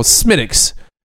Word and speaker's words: Smithwick's: This is called Smittix. when it's spelled Smithwick's, I don't understand why it's called Smittix.